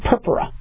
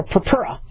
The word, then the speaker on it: purpura: Okay, you know, we don't want anybody to spontaneously bleed, but we call those bruises in medical terminology purpura. Or purpura, right? I always put my accent on the wrong thing, but I called it purpura, and I think they corrected me as purpura.